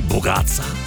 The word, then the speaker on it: bougatsa: I was not sure I was pronouncing the name of that Greek dessert properly, bougatsa. Apparently, I was doing a pretty good job, but she knows because she's been married in a Greek family for 25 years and sometimes still struggles.